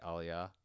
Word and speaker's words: Aaliyah: Aaliyah. That's how you say her name, right? Aaliyah? – Yeah, that's how you say it.